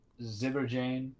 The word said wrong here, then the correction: Zibanejad: I cannot say that correctly. Zibanejad. Zibanejad. Mm-hmm.